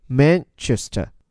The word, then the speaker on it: Manchester: 注意这个城市 Manchester，它的发音重音在第一个音节 Manchester。这座英国西北部大城市。拥有两家世界级的球队，一个是这里的 Manchester United 曼联，另一家则是历史和当地名望与它不相上下的 Manchester City 曼城。